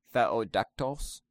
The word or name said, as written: Theodektos